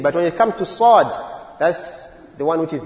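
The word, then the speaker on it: Sad (صاد): And very common mistake is Sad. You see, Sad, the pronunciation of Sad is very close to Sin.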